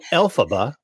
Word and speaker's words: Elphaba: So Elphaba, which sounds like, you know, letters in a row.